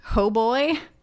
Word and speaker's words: Hautbois: It's apparently, supposedly, the English pronunciation is hoboy. But it's spelled H-A-U-T-B-O-I-S. – What? – And every time I see it, I say to myself, hot boys. In French, I think it should be pronounced hautbois.